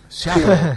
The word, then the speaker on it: Seattle: ¡Qué buena pronunciación! Seattle, sí. Seattle, es así, se pronuncia así.